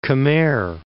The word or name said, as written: Khmer Republic